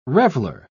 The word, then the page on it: reveller: British English